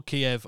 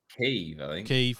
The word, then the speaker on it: Kyiv: So, a mysterious flash of light that lit up the skies over Kyiv, or Kiev. I don't know how you pronounce it anymore. So many people are talking. – Kyiv, yeah, we'll go for Kyiv.